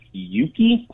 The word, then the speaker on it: Euki: There's also another app called Yuki, I believe it's pronounced, E-U-K-I. – E-U-K-I, okay.